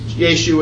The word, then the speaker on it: Yeshu: Yeshu would have been the Hebrew way of pronouncing his word, or his name.